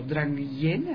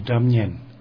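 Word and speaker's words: dramyin: El maestro interpretará temas junto a instrumentos conocidos como Drang Yen, así se pronuncia, laúd tibetano. – The Tibetan lute is called Damyen. – Damyen. La pronunciación es un poco diferente.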